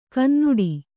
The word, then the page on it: ಕನ್ನುಡಿ: Kannada